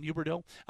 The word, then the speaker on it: Huberdeau: And I think Lindholm, Toffoli, and Huberdeau, let's try that again. Trying not to pronounce the H as much as it's habit. Jonathan Huberdeau.